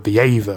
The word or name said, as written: Aether